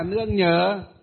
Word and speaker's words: nương nhờ: Lương nhờ ạ. Hay không, thầy đọc đúng mà. Thầy đọc đúng à. Lương nhờ hay là nương nhờ? Nương nhờ chứ không phải là lương nhờ.